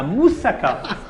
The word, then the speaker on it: moussaka: Ça s'appelle la moussaka. Comment? La moussaka. Je le prononce bien, chérie? – Oui, comme les Grecs même.